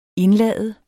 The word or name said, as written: indlade